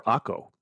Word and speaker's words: Akko: It's Acre or Akko. A-K-K-O is the way it's spelled.